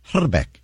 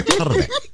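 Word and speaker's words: Hrbek: I speak a little bit of Czech, and the actual correct pronunciation of his name is Herbeck. You got to roll the I. Herbeck.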